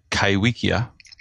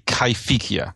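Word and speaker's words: Kaiwhekea: There's a plesiosaur from New Zealand called Kaiwikia, or supposedly pronounced properly Kaifikia, but not even its describers use that name, Kaiwikia.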